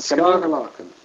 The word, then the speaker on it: Scarlaken: si dice, come si pronuncia in Scarlaken. – Scarlaken.